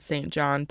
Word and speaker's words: St. John: And just then, a man named St. John Rivers, remember, his name is spelled St. John, but it's pronounced St. John.